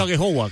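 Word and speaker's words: Aghehowa: He dicho Agehowa, ¿cómo se pronuncia? – Sí, bien, la verdad que... Muy bien, así, muy bien. – Muy bien, ¿no? Samu Agehowa.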